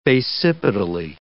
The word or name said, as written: basipetally